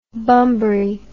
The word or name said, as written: bumbry